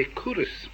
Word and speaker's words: Culross: There's another place I remember too, a place in Fife called Culross. You say Cooris.